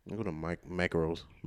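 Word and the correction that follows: Marco's: You know what I'm saying. – Marcos? – Yeah, there you go. – You know they said about a slice. – No, because look, because it's spelled like macro, like macro pizza. – Oh, it is. Marcos.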